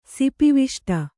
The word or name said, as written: ಸಿಪಿವಿಷ್ಟ